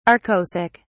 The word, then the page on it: arkosic: British English